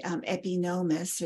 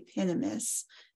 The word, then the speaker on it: Epinomis: And that shows up in Plato's work, the Epinomis, or the Epinomis, depending on how you want to pronounce it, E-P-I-N-O-M-I-S, that most scholars now think was written by one of Plato's students called Philip of Opus, O-P-U-S, who was an astronomer.